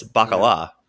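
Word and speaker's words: baccalà: I was on the upper and you put the you put the emphasis on the wrong syllable. It's bacala. Yeah.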